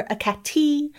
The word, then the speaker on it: Hekate: Or Hekate. The H being silent or not, but still putting the emphasis on that middle syllable, Hekate.